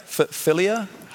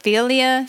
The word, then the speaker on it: philia: It's philia, philia? How do I say that? – Philia.